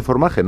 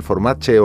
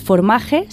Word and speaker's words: Formaje: Y se dice Formaje, no es catalán Formache o, nada, ¿no? – No, efectivamente, es, es Formaje, se escribe con J